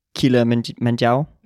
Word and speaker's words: Kilimanjaro: Kilimanjaro. Er det rigtigt udtalt? Ja, det er det.